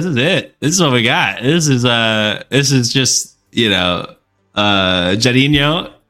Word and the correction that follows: Jairzinho: or is that how you pronounce it? Jairinho? – Jairzinho. – Jairzinho. I forgot the Z, my apologies.